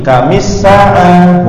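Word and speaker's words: Kamis sa'ah (كم الساعة): Contohnya ditambah ya kam as-sa'ah, bacanya gimana? Kamis sa'ah.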